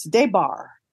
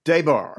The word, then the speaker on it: Des Barres: The S is silent, by the way. It's Debar. – Debar.